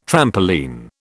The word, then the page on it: trampoline: British English